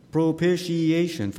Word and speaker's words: propitiation: We read there's this word called propitiation, big word, propitiation, five syllables.